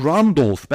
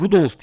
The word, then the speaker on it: Randolph: Dwayne John Randolph ben Rudolph diye daha önce telaffuz etmiştim diye hatırlıyorum yalnız telaffuz etmişim Dwayne John Randolph ödülü aldı.